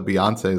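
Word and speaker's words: Beyoncé: It's Texas Hold'em by, I'm going to mispronounce as Beyonce. – No, you nailed Beyonce. It. Yeah, her name is Beyonce. Oh, good. Just one word.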